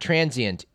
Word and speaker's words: transient: But the word T-R-A-N-S-I-E-N-T, it's got two syllables, not three. It's not transient, it's transient.